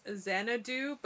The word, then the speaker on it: Xanadeux: Oh, my very last note is then it shows the production companies, and one of them is Xanadu, but it's spelled X-A-N-A-D-E-U-X, and that's what I'm gonna name my child. – Xanadu? – Yeah, but spelled like that.